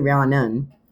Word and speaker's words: Rhiannon: Hers is spelled R-I-H-A-N-N-A. Rihanna is spelled Ariannon. It's actually Rhiannon. R-H-A-N-N-O-N.